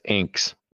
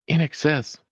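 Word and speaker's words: INXS: Well, it's funny as in the, you know, when I was in high school, I pronounced that as Inks. – A lot of people did. – You're not the only one. – Yeah, I was like, why do y'all like Inks? It's In Excess.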